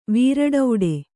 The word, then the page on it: ವೀರಡೌಡೆ: Kannada